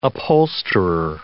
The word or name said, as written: upholsterer